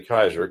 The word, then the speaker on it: Caesar: The reason I say Kaiser, because that's the Anglo pronunciation of Caesar.